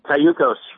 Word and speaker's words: Cayucos: How do you say that town, Cayucos, California? – Cayucos. – Cayucos. – Yeah, Cayucos.